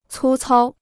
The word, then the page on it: 粗糙: cū cāo